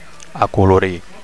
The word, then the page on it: acolorir: Catalan